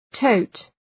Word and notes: tote: təʋt

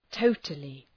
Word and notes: totally: ‘təʋtəlı

